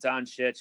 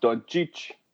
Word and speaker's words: Doncic: I think his last name is pronounced Doncic, but I'm not going to do that over and over again for the rest of my life. – But the Americanized version would be Don, like the Don, kiss the ring, and then Cheech, like Chich or Cheech.